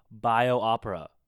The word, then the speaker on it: Biospera: Basically, it's called Improv by Biospra. Okay, I'm saying it wrong. B-I-O-S-P-E-R-A. It's like Biopera. Yeah, there you go.